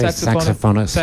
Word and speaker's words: saxophonist: Sasophonist, is that how you say it? Sasophonist, I think that's right. – No, I say saxophonist.